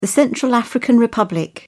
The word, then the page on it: Central African Republic: British English